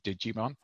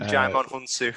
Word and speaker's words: Djimon Hounsou: Uh, we've got um, I I never I never want to say his name because I always nearly call him Digimon. Uh, Djimon Hounsou. Djimon, Hounsou as uh, as Papa Midnight, who's like a witch doctor who uh, kind of helps Constantine out.